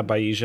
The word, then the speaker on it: Bayesian: Oh, I just tried to run my Bayesian. Bayesian, is that how you pronounce it? – Yeah, Bayesian.